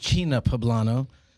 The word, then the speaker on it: China Poblano: Is this the right name of the restaurant, China Poblano? – It's actually pronounced China Poblano.